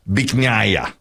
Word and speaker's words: Bich Nga: Now, here's how the restaurant is spelled. B-I-C-H-N-G-A. So he thought it was, Yeah. – Now it's pronounced Biknaya.